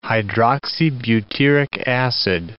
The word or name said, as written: hydroxybutyric acid